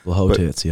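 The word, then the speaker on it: Błachowicz: Blachowicz, yep.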